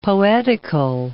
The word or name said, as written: poetical